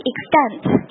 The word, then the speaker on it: extent: In most cases, 或者 in all cases, 或者 broadly speaking, by and large, to a great degree, or to a great extent, 注意 extent 这个发音哈，extent 这个第一个 t 我们要把它做一个浊化的处理，发成 the 的这个音哈，to some extent, right.